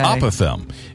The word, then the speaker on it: apothegm: P-O-T-H-E-G-M. – Oh, come on. – The old silent G routine. – Like a phlegm kind of word? – Exactly. – Okay. – Apothegm.